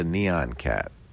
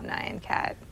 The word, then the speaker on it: Nyan Cat: How about the Neon Cat? – The Neon Cat? – Is that how you say it? – Oh, Nyan Cat. – Nyan? – Well, it's not Neon. That's N-Y-A-N. A whole different thing.